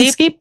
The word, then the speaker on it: Inskeep: This week, and you should look it up, Steve Inskeep. Did I say that right? – Steven Inskeep? Yeah, from NPR.